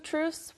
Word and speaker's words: truths: 그런데 two truths 발음하기가 너무 어려워요.